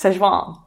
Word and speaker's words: Szechuan: Yes, Szechuan.